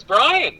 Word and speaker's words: Brian: Hi, my name is Brian.